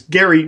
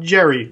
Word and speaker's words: Gerry: I pronounce it gerrymandering because I know that it's named after Elbridge Gerry, whose name is Gary, not Jerry, like it's often pronounced.